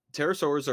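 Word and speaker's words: pterosaurs: Terra Swords are an extinct clade of flying reptiles. They are not dinosaurs, but they are closely related to dinosaurs. The P is silent.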